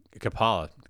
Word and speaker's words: Ciapala: Uh, again, Derek Cap- uh, Capola is Kapala, can't even say his name, uh, has an interview with myself here right after this.